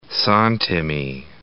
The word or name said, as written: santimi